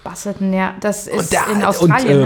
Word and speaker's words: Busselton: Es ist ja schon wirklich nur ein Side-Note, aber es war der 70-3, wie spricht man es, Busselton, ne? – Ja, ja.